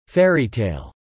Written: Fairytale

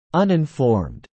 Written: uninformed

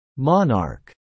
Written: monarch

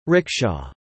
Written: rickshaw

